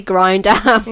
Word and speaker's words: Grindr: But I am 99.9% sure it was probably Grindr. – Indeed. – So it rhymes with binder.